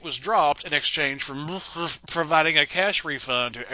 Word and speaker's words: MSCHF: Nike not only disavowed any connection with the shoes, they sued Mischief, M-S-C-H-F, which I assume is pronounced Mischief. There's no vowels. Just run all that shit together. Mischief.